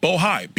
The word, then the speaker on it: Bohai: Bohai, B-O-H-A-I.